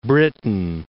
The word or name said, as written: Britten